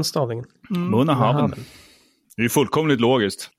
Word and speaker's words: Bunnahabhain: Bonahaven.